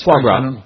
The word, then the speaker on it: foie gras: Foie gras. How do you say that? – That's foie gras. That's right.